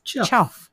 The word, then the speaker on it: chough: I also looked up about birds and found that, yes, there is what looks like the word cough, which is C-H-O-U-G-H, but it's pronounced chuff.